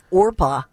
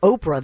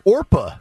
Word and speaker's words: Orpah: This is a woman who, her name is Orpah, which is a Bible name in the book of Ruth. And they called her Oprah. They mispronounced her name.